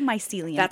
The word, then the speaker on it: mycelium: And so, of course, our brain networks match that of the mycelium. – I say mycelium. – You're saying it right.